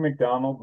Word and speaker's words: McDonald: Yeah, I'm Drew McDonald with Facet.